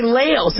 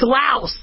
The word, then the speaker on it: Laos: So, you know, maybe before you guys start getting all mad at me, that I'm, you know, a lot of you guys say Laos. It's Laos.